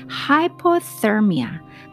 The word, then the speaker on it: hyperthermia: 자, 하이퍼인데 하이퍼서미아. 발음을 제대로 집중해서 안 들으면 헷갈리실 수 있어요. 하이퍼 퍼 R 사운드가 있는 것이 고체온증이에요. 하이퍼서미아. 그리고 저체온증은 R 사운드가 없어요.